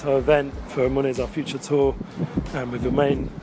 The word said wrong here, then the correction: Uman: Uman, Ukraine.